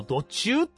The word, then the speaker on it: 土柱: そしてですね、もう一つ、ちょっとね、帰りに寄ったんですが、妹が非常に感動した、その、アハの土柱っていうのがあるんですよ。土の柱と書いて土柱ですね。